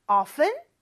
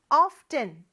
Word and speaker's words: often: 뭐 이런 말이었는데 visit이 뭐 물론 방문하다 라는 좀 딱딱한 말도 되지만 그냥 가다 라는 의미로 많이 쓰이고 often은 종종이라는 뜻이고 발음은 두 가지가 있다고 그랬죠. often, t 발음 없이 할 수도 있고 often, t를 발음할 수도 있다.